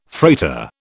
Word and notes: freighter: British English

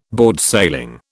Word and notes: boardsailing: British English

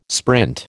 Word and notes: sprint: American English

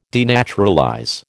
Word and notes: denaturalize: American English

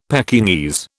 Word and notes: Pekingese: British English

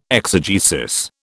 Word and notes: exegesis: British English

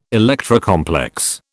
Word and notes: Electra complex: British English

